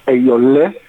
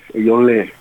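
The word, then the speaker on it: Eyo'nlé: Alors, tu fais partie du groupe, comment ça se prononce d'ailleurs? – Le groupe Ayolé. – Ayolé. – E-N-L-E.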